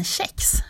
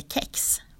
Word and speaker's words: kex: Kexförespråkarna lutar sig mot grundregeln att k nästan alltid uttalas sh före mjuk vokal, såsom i kedja och kela. De som vill ha k-uttal lyfter istället att ordet kommer från engelskans cakes och att det uttalas med hårt k.